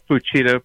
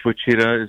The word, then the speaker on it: Fuchida: So let's begin with the commander, the Japanese commander, Mitsuo Fuchida. Is that the way it's pronounced? – Yeah, that's close enough. Fuchida, probably. Okay. Mitsuo Fuchida is the way we say it in English.